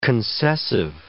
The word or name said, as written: concessive